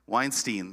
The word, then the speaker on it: Weinstein: Weinstein, there you go.